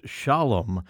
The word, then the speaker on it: Sholem: In fact, even on the single, it says S-H-O-L-E-M, pronounced Shalom, Eartha Kitt.